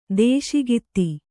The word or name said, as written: ದೇಶಿಗಿತಿ